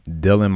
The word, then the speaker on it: Dillon: So you're originally from Dillon, Montana. Did I say that right? – You did. Good job.